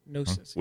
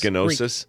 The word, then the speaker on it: Gnosis: Gnosis. – With a silent with a G. – Yes. Gnosis.